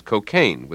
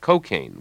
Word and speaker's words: cocaine: Up to a generation ago, the only standard pronunciation was cocaine, with the stress on the second syllable, as in Cole Porter's line, "I get no kick from cocaine." Now it's common to hear newscasters talking about cocaine, with the stress on the first syllable, a street pronunciation that comes from the speech of inner-city blacks.